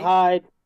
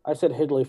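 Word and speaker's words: Hidlay: H-I-D should be Hid, right? – Hyde. I know. I said Hidley for 10 years. – So it's Hyde. It's Hyde.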